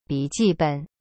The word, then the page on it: 笔记本: bi ji ben